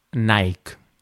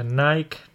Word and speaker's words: Nike: no tengo, bueno, no sé exactamente, pero sé que en Estados Unidos más bien lo pronuncian como Nike. Nike. – Nike, de acuerdo. Pues eh, Nike, Nike.